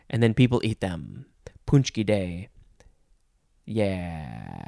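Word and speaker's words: pączki: It is Punchki Day. It is spelled P-A-C-Z-K-I.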